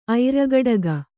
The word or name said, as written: ಐರಗಡಗ